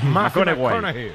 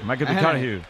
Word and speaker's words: McConaughey: Mateo McConaughey. – Sí. Sí. – Hay que ir corrigiendo la pronunciación. – McConaughey. McConaughey.